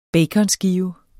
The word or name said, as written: baconskive